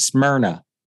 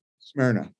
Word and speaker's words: Smyrna: We pronounce it Smyrna. – Smyrna. Perfect. Smyrna.